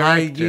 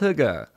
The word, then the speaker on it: hygge: High gi. – It's also, by the way, it's pronounced hygge.